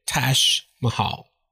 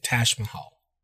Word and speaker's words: Taj Mahal: Okay, Taj Mahal. That's the way we say it, Taj Mahal. Okay? I know it's a weird word, a pronunciation for a place, but in English we say, as far as I know, I mean, in my English I would say Taj Mahal.